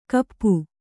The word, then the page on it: ಕಪ್ಪು: kappu